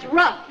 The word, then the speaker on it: rough: Cutting wood all day made his hands strong and raw. One day... – That's rough. – Spelled the same way as the other. O-U-G-H.